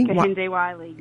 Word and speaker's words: Kehinde Wiley: It's the Barack Obama by Kehinde. Am I saying that correctly? – Kehinde Wiley. – Yes. Kehinde Wiley from 2018.